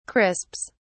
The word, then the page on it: crisps: British English